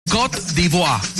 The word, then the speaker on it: Côte d'Ivoire: Cuando dijo, no me acuerdo cómo pronunció en pronunciación en francés, es Côte d'Ivoire.